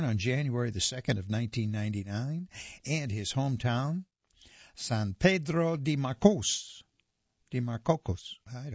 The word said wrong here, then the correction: San Pedro de Macorís: San Pedro de Macorís, Dominican Republic.